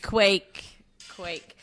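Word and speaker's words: kveik: Can you spell it? – Yes, I can. It's K-V-E-I-K. Do we have a Google pronunciation? a Google pronunciation. Can you please put that in a sentence? – Yeah, hang on. I got it. Damn it. Pronunciation here. Oh, okay. Hang on. – Hang on, he said he's got it here, people. Hold on. – Quake. – Yeah, because, yeah, okay.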